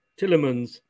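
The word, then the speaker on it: Tielemans: But I do like, what's his name for you guys? Talisman? I never know how to pronounce it. – Tillemans. – That's the one.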